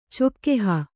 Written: ಚೊಕ್ಕೆಹ

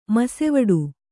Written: ಮೋಸವಡೆ

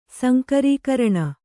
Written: ಸಂಕರೀಕರಣ

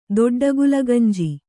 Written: ದೊಡ್ಡಗುಲಗಂಜಿ

